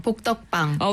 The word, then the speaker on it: 복덕방: 발음이 참 어려워요. 아, 여러분 한번 해보세요. 이게 읽을 때는 괜찮거든요. 근데 복덕방. 이게 은근 어려워요. 그 기역기역 받침이 참 쉽지가 않습니다. 한번 부탁드려요. – 복덕방.